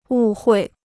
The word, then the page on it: 误会: wù huì